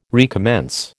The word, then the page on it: recommence: American English